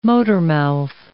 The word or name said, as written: motormouth